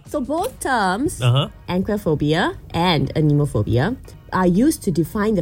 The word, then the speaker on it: anemophobia: Anemophobia. – Anemophobia? Mm. Like Finding Nemo? Nemophobia? – Anemophobia. – First was nomophobia, now anemophobia. So Nemo, how do you spell it? – Anemophobia. A-N-E-M-O-P-H-O-B-I-A.